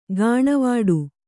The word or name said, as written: ಗಾಣವಾಡು